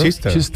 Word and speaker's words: Chichester: Chichester, claro.